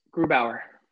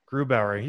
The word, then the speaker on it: Grubauer: The Avs, Francoeur or Gruber? – Grubauer. – Grubauer. You're so good with your pronunciations.